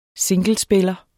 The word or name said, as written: singlespiller